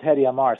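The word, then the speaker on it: Hadi Amr: Yeah, his name's Hadi Amir, so you pronounced it quite right.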